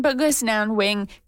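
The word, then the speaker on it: Bkejwanong: Kiknazwe is Cree from the Buck has, I'm going to say this wrong, Buck has Wanang, let me try that again, Bugis Nan Wing territory, also known as the Walpole Island First Nation in southern Ontario.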